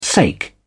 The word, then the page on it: sake: British English